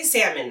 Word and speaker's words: salmon: I say salmon now. – Okay, good. You don't pronounce the L because that's...